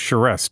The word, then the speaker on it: Charest: You know, it's funny, Andrea, to look at your last name, and I'm told it is pronounced Charest. Do I have that right? – You betcha.